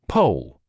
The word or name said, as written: pole